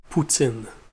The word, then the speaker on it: poutine: Poutine.